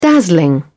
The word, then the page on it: dazzling: British English